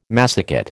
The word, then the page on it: massicot: American English